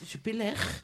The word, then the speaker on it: Jupiler: Oh, prononciation la Non, ça se prononce Jupiler.